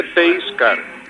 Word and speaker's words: pace car: Por cierto, Paco, una cuestión, que se dice pace car, ¿eh? – Ya. – Porque además no se escribe peaje, sino solo pace.